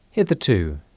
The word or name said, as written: hitherto